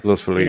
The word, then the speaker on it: Los Feliz: Wait, what's the correct pronunciation? – Or Feliz, or like Los Feliz.